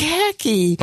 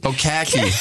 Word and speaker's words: khaki: It's khaki. – Oh, khaki.